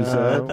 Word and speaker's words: Z: We can do Zed. – That's how it's said in the film. – They do say it's Zed in the movie. – This is America. – We say Z.